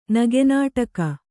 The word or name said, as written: ನಗೆನಾಟಕ